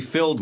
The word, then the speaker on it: filled: She filled my glass. Filled. – Las letras ED al final de un verbo suelen indicar que algo ha sucedido en el pasado. Se pronuncia casi siempre solamente como D.